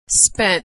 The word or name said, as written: spent